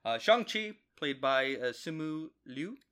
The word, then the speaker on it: Shang-Chi: Shang-Chi. Shang-Chi.